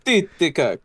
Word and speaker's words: Titicaca: Lake Titicaca. – No, say it in the proper pronunciation. – Titicaca.